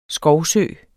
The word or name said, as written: skovsø